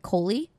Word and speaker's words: Koli: Y'all, we are here with, and make sure, I want to make sure I'm pronouncing this right, Koli.